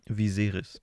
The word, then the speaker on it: Viserys: Viserys.